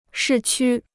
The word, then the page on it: 市区: shì qū